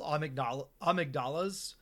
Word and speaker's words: amygdala: like monsters called amygdalas. – Amygdalas? – They're pronounced in the game amygdala, okay. But it's spelled the same way.